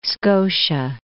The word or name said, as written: Scotia Sea